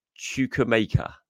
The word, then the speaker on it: Chukwuemeka: I've had a proper look at it this time, and it's Chukwuemeka. There you go. I think that is about right.